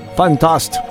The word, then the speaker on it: Fantast: And how do you say that in German? – Fantast. – There you go.